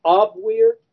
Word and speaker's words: Abwehr: who was head of the Abwehr, A-B-W-E-R-T, the W's are pronounced as a B in German.